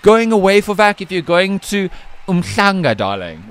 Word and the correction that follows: Umhlanga: No, Umschlanga. Umschlanga. So, let me pronounce it correctly, Umschlanga.